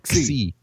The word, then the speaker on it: Xi: No. It's a X. – Yeah. – It's a KS sound. – Xi.